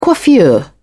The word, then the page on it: coiffure: British English